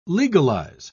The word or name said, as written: legalize